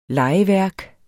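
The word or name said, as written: LEGEVÆRK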